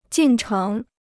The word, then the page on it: 进程: jìn chéng